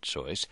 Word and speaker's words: Seuss: She was German and pronounced it Seuss. He didn't mind that Americans called him Seuss, though, because it conveniently rhymed with Mother Goose.